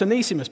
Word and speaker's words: Onesimus: was actually pronounced Onesimus, but I learned something new. So it's Onesimus, but if I do slip into Onesimus, you know where it's come from.